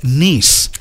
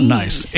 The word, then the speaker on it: Nice: The original name is not nice.